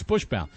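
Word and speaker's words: Buchsbaum: Well, he actually, and if you look at his name and how it's spelled, it's B-U-C-H-S. But when you look at it, you just say, you see Bush, you know, even though you see B-U-S-C-H instead of B-U-C-H-S, and you just think it's Buxbaum. And so that's the way you pronounce it.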